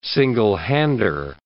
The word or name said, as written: single-hander